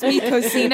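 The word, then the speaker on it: Mi Cocina: and I should have the yummiest lunch at Mikokina. Because he didn't know how to pronounce Mikosina.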